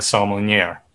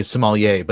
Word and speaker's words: sommelier: sommelier, right? – Well, if you pronounce it correctly, it would be sommelier, but yeah.